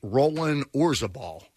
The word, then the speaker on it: Roland Orzabal: am I saying this right? Roland Orzabal.